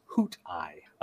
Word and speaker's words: Hoothi: Um, so Maren of the Sisterhood of Karn, uh, the traveling Sisterhood of Karn, whatever, yeah, mentions a race called the Hoothi, or at least that's how I'm pronouncing it, mm-hmm. – uh, who travel in silent gas dirigibles. Uh, they're called the Muthi, according to Terrance Dicks's script. But since the Grenville pronounces the name as Hoot Eye. Um, this hmm. is quite clear on the DVD.